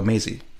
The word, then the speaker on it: Maisie: Is it pronounced Mazzy? Is it not Maisie? – Oh, Maisie. – Is that Maisie? – I don't know. – Mazzy or Maisie.